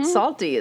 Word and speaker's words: Psalty: We put on at least one big musical production every year, including Salty, the singing songbook. Okay, Salty is spelled P-S-A-L-T-Y.